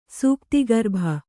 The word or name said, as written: ಸೂಕ್ತಿಗರ್ಭ